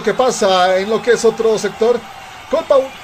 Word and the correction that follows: Calpa Huira: Calpa Huira perdón, la pronunciación